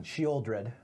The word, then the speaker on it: Sheoldred: Sheodred, the Whispering One. – Sheoldred. – No! 7 mana, 6. – There's an L. – It's a silent L, okay?